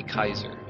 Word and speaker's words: Kaiser: I am your host, Minnesota Chiefs fan, or Seth Kaiser, spelled like, you know, Keysore, but we go by Kaiser.